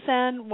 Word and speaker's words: Senn: E.L. Sen, uh-huh. And just for your listeners, that's spelled S-E-N-N, but he himself made plays on the fact that it sounded like sin, like, you know, something bad you shouldn't do.